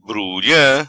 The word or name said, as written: Brulha